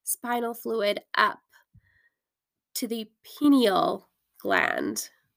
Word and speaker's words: pineal gland: Is it the penile gun? Sound like I'm saying penis. let me go quickly get Google to pronounce this for me and make sure I'm saying it right. Haha. Okay, I'm back. It's pineal gland. I knew there was something wrong with the way I was pronouncing it.